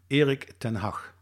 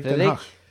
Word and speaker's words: Erik ten Hag: First of all, can you teach us the right pronunciation? – Erik ten Hag. – Okay, we'll have you to practice that. – No, but you should, in Norway, you should be able to pronounce it easy. Erik ten Hag. – Shit. – One more time. – Erik. Ten Hag. – Ten Hag. – Yeah. – Was that good? – Ten Hag. That's it.